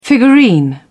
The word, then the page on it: figurine: British English